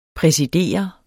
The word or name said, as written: præsidere